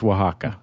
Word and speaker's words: Oaxaca: and the fact that it's pronounced Oaxaca.